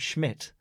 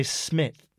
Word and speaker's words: Schmidt: Like, a lot of people in England apparently can't say the word Schmidt. – Right, okay. – They say Smith.